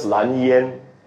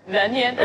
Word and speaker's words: Lan Yan: Okay, for literal translation, it's just Lan Yan. – Lan Yan.